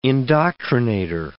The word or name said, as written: indoctrinator